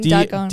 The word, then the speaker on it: Dogon: Probably pronounced that wrong. I'm sorry. – The Dogon. – I think it's the Dogon. – I know, I was saying Dogon. – D-O-G-O-N, the Dogon. But this tribe wasn't discovered by the Western world until like 1936, I believe, by this one anthropologist.